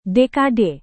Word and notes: dekade: Indonesian

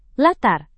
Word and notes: latar: indonesian

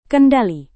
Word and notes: kendali: indonesian

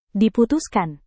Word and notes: diputuskan: Indonesian

